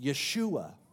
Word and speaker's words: Yeshua: Or as it would be pronounced in Hebrew, it would be pronounced Yeshua, which literally means Yahweh saves or Yahweh rescues.